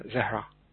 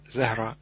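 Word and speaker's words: Zehra: Am I saying it right? Sahar? – Zahra, I think.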